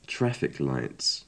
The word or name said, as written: traffic lights